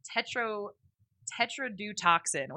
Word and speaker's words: tetrodotoxin: There's also so I started researching about the specific toxin, which is tetrodotoxin, which I think is going to be the secret phrase. – You're going to need to spell that. – T-E-T-R-O-D-O-T-O-X-I-N.